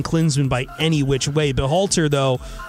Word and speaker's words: Berhalter: I want to look at the coach, Greg Berhalter. Is that how you pronounce it? – Berhalter, yeah.